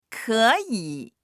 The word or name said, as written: ke3yi3